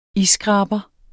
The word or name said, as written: isskraber